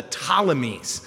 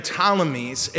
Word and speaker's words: Ptolemies: And the ones who took what we now call Israel were called the Ptolemies. And it starts with a P. The P is silent. And I don't know why. Just take it out. But they left it there. So we have the Ptolemies, and they were classic Greeks.